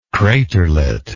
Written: craterlet

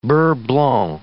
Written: beurre blanc

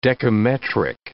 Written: decametric

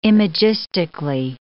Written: imagistically

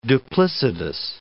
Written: duplicitous

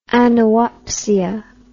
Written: anoopsia

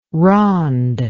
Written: ronde

